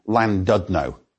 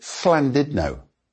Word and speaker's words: Llandudno: English people pronounce its name Llandudno. However, the two L's at the beginning of the word should be pronounced fl, so try to pronounce it Llandudno.